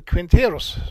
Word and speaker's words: Quinteros: Eddie Quinteros, eller Quinteros, så ska det uttalas då, nästa artist som jag ska presentera.